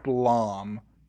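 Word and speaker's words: BLAME!: Okay, well, it's pronounced like Blom, but it's spelled Blame.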